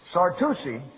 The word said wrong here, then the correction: Sarducci: Sarducci. Sarducci. You always pronounce an Italian C before an I or an E as a CH.